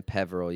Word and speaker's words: Peverill: Okay, so my name on Facebook is Marcus and then Peverill. You spell it P-E-V-E-R-I-L-L. And then my Twitter is Bubba Marks, B-U-B-B-A-M-A-R-X.